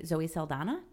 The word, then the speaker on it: Zoe Saldana: And one of the characters played by, I hope I'm saying her name correctly, Zoe Saldana.